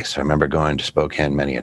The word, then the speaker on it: Spokane: Call it Spokane. – Spokane. Yeah. That's why it's spelled.